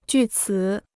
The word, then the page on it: 据此: jù cǐ